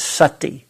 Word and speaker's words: sati: It's called, the word for mindfulness is sati. S-A, it's a short A, so it has an U sound. Sati, S-A-T-I.